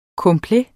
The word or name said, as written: complet